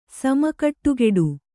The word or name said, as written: ಸಮಕಟ್ಟುಗೆಡು